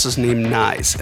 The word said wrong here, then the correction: Nies: and I, I feel kind of silly about it because I've known him a really long time, but here I am mispronouncing his last name. So I felt a little silly, so it's actually pronounced knees.